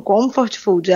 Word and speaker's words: comfort food: É isso mesmo a pronúncia? – Bom dia, Ricardo. Primeiramente, muito obrigada pela oportunidade de estar aqui falando mais uma vez sobre um assunto tão importante. A pronúncia é essa mesmo, Comfort Food.